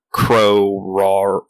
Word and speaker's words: Koror: We're somewhere in the Koror. Is it pronounced correctly? Koror. Koror. – Yeah, it's like an a Crow Roar.